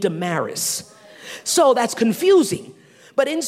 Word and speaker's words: Damaris: And a woman named Damaris. Accent, the syllable is the first syllable, has the emphasis.